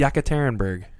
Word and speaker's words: Yekaterinburg: Yekaterinburg.